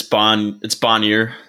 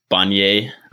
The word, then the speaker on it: Bonnier: Yeah, it's Bonnier. It got Americanized. I'm French Canadian, so it was Bonnier.